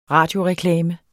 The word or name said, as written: radioreklame